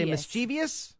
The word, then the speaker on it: mischievous: Do you say mischievous? – Yes. – Yes. – I do. – Well, it's not a four-syllable word. It's actually a three-syllable word. It's mischievous.